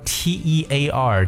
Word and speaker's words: tear: down 这个 tear 这个单词呢是 t e a r，可能会有些人觉得，哎，这个词不是读 tear 吗？表示眼泪，没错。可是呢，当 t e a r 这个词做动词的时候，它的发音就发生变化了，要读成 tear。